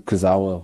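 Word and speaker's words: Kurzawa: Kuzwa, I never know how to pronounce his name, but the left back is also someone Zawa. – in Kuzawa who has never been that great.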